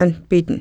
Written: npitn

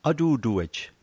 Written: atutuej

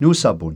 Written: nusapun

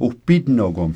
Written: wpitnokom

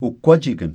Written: wkwajikn

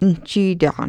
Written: njitaqn

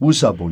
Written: wusapun

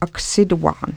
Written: ksituaqn

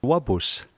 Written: wapus

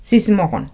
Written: sismoqn

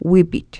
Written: wipit

